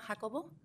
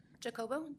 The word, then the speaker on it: Jacobo: My name is Fatima Jacobo, and I'm a resident of Layton. – Say your last name again, please. – Fatima Jacobo. Jacobo, J-A-C-O-B-O.